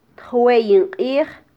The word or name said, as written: tjweyin qij